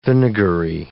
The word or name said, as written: vinegary